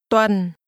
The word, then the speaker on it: tuần: Tuần. Tuần. Tuần. – This is pronounced with a low, falling tone.